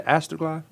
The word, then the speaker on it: Astroglide: What is it, astroglide? You should know. – You said it right.